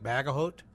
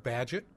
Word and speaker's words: Bagehot: The name is spelled B-A-G-E-H-O-T, so it looks like Bagahot. It is pronounced Badgett.